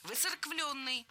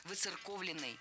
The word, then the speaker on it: выцерковленный: Выцерквленный. Здесь сразу две ошибки в одном слове. Во-первых, пропущено «о». Во-вторых, неправильное ударение. Слово должно звучать так – «выцерковленный». В качестве шпаргалки предлагаю однокоренное прилагательное. «Церковный», «выцерковленный». Далее у нас на очереди краткая форма того же слова – «выцерковлены».